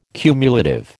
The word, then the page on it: cumulative: American English